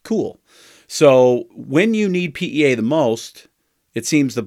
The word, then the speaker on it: PEA: Am I saying that right? It's making less PEA. – Correct.